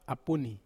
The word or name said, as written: apuni